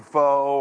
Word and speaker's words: pho: Because my daughter can get Panda Express, I can go get some Rubio's, wife can go get some pho or whatever she wants, or however you pronounce it.